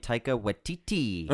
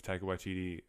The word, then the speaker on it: Waititi: They kept saying his name Taika Waititi at the Oscars. They kept just like pronouncing the TT a lot.